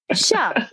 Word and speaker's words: tja: Sha is T-J-A. Sha. Yeah, T-J-A. Sha. And the Shaba. – Sounds so corny. – It is spelled T-J-A.